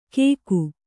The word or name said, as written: ಗೇಕು